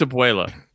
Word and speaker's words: abuela: I think it's abuela. It's not abuela.